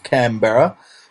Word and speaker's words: Canberra: Canberra. – And also all your prostitutes and drugs explosives. – That's right, uh-huh. That's right. I had to say it right because Jake said it wrong when he went on another show.